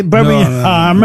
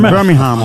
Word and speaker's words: Birmingham: Voilà, on ne prononce pas le G, Birmingham. – Birmingham.